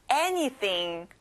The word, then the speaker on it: anything: 아무것, anything. 앞에 에 강세를 두세요.